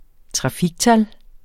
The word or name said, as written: trafiktal